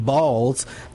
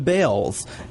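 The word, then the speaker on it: Baals: Did change the pronunciation of their last name from Balls to Bales.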